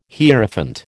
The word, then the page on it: Hierophant: American English